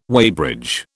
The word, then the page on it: weighbridge: British English